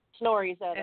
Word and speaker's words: Snorra Edda: the Poetic Edda and how do you say this, Snorra Edda? – Snorri's Edda. – S-N-O-R-R-A? – Yeah.